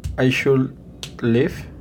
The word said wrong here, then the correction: lift: Recuerda de pronunciar la T al final de lift. Porque si no se escucha como live, de vivir.